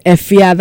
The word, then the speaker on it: Efya: But her stage name is Efia, that is spelled E-F-Y-A.